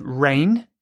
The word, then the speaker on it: reigned: This word, it sounds the same as the word rain from the sky, but the spelling is different. It's R-E-I-G-N. So E-I-G-N. There's a silent G in there.